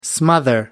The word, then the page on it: smother: American English